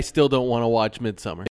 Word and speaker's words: Midsommar: No, it's just pronounced Midsommar.